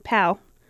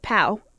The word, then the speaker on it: Pau: but on this day, and 5 hours ago, according to Twitter, Paul Gasol. – Pow. – I'm See, y'all don't even know how to pronounce his name. It's Pow. P-A-U.